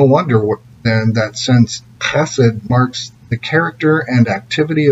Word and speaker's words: chesed: My favorite word in all of Holy Scripture is the Hebrew word chesed. It's pronounced with that guttural sound, and it's such a rich word that no single English word can capture its depth. So chesed